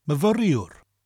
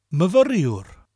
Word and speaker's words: myfyriwr: So, male student is myfyriwr. Myfyriwr. Myfyriwr. Myfyriwr. And don't forget to roll your R's in the middle of the word. Mavr, and at the end of the word, iwr, mavriwr.